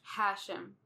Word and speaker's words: Hashem: Hashem has emphasis on the first. – Hashem.